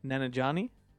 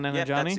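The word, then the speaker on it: Nanjiani: Nanajani? – Yeah. – Come on, Nanajani.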